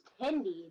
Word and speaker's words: Hindi: And then I made a funny little one that was Hindi or die. – No, it's pronounced Hindi though.